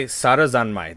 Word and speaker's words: Sarazanmai: I meant to say Sarazanmai. That's Sarazan. That's how it's pronounced.